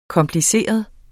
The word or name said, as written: kompliceret